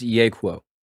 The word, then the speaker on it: Yeahquo: It's Yeahquo.